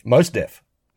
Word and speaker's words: Mos Def: Is it Mos Def? Mos Def? – Mos Def.